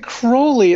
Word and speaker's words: Crowley: Yeah, we're talking Crowley is how you pronounce it.